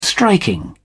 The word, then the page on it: striking: British English